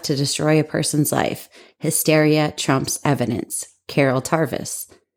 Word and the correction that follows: Tavris: And I said that lady's name wrong. It's Tavris, not Tarvis.